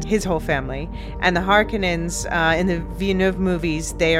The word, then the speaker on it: Harkonnens: the Harkonnens, and yes, I'm using the more Finnish pronunciation now, thank you Marilyn, and the Atreides.